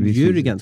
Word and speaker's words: Jürgen: Nu är det ju en ren gissning och då måste man ju nästan citera liksom, Jörgen Klopp, heter han så? Liverpool-tränaren som menar på att... Men, Jürgen, ska vi säga Jürgen?